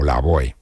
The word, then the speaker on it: Lavoe: Como te dije antes, se titula el disco La Voz, pues como su apodo, que no te he contado que ese apodo se debe a una deformación de la pronunciación en francés de La Voz, es decir, La Voix, terminado en X, V-O-I-X, La Voix. Entonces suena como La Voe, y así se quedó, Héctor Lavoe.